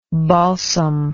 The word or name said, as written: balsamy